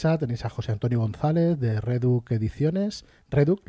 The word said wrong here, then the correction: Redac: ¿lo he dicho bien? – ¿José? Redac, sí. – Redac, no te preocupes.